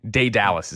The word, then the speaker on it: Daedalus: Daedalus is how you say it.